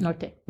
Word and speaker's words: Norte: Is it pronounced Norte? – Norte. – Norte, okay. And so I thought,